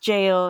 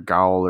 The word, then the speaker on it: gaol: Like, it's just pronounced jail.